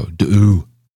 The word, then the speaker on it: Dehu: Dehu, of course, pronounced without the H. So Dehu.